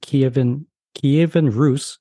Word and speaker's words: Kievan Rus: I know that you created it as a tabletop simulation, the Mongol invasion of Kievan Rus. I don't know if I pronounced that correctly. – Yep.